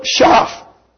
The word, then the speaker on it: Schaff: And in one of the sessions one night, I quoted Philip Schaff, I called him. So he corrected me on that and said that the correct pronunciation of that word is Schaff.